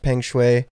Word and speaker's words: Peng Shuai: I heard it pronounced Peng Shuai.